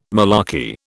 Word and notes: malarkey: British English

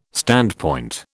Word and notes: standpoint: British English

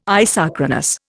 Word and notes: isochronous: Indian

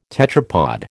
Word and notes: tetrapod: American English

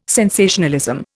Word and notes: sensationalism: Indian

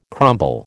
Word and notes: crumble: American English

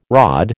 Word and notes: rod: American English

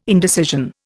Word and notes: indecision: Indian

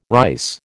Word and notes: rice: American English

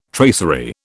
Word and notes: tracery: British English

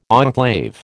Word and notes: enclave: American English

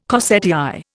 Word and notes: corsetière: Indian

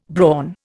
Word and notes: brawn: Indian

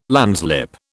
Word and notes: landslip: British English

